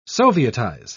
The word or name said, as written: sovietize